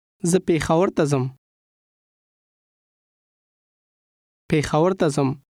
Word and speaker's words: Peshawar: Peshawar, as we say it in English, is the southern pronunciation with the sh sound. Repeat the northern pronunciation after the speaker. – Peshawar.